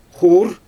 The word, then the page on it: xuur: Xyzyl